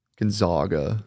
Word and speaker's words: Gonzaga: If you mispronounce his school's name. – Gonzaga. – It's Gonzaga.